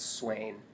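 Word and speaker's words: Siuan: and then, like, within the first 100 pages, you get this really interesting conversation between Moraine and Swan Sanche. Yes? – I believe it's Swain.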